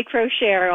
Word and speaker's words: Crochiere: Yes, I say Crochere, although the family has no consensus, so you can say anything.